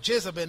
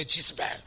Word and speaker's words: Jezebel: You may be provoked at my pronunciation of Jezebel. That's just my French influence. You know, I may find it difficult to say Jezebel, as you say. No? Because we say Jezebel.